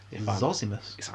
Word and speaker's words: Zosimus: Right, according to one of the small snippets we have, this is Zosimus... – Zosimus. – I'm pronouncing that correctly.